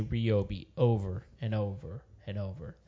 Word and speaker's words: Ryobi: I say Ryobi. – There you go. – Of course you do. – Good man. – Because you're a consumer. – No, because it's like my name, Rye.